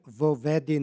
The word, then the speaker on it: vovedi: Ovo je sintagma vovedi, sastavljena od osnovne reči vedi, koji je oblik imperativa glagola vesti, i predloga v, slivanjem predloga i reči osnovne, dobili smo jednu reč i dva ista vokala na početku. Stoga u izgovoru dodajemo takozvano izgovorno o. To je pravilo da kada reč počinje vokalom kojim počinje predlog koji stoji ispred te reči, a te reči se slivaju, između njih se dodaje izgovorno o.